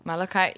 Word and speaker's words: Malachi: how do you say Malachi? – Malachi, yeah.